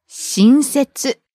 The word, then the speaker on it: 新設: でも、このレッスンに出てきた to newly establish は、親切。 – starts low and rises on the second syllable. うん。 – 親切。